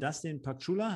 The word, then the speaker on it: Dustin Paczulla: Auf jeden Fall von Dustin Pakchula, habe ich es richtig ausgesprochen? – Absolut, ja.